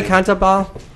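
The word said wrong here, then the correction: Cantabile: So Kantabide. – Did I pronounce it? Thank you. I'm sorry. – That's how the Japanese pronounce it anyway.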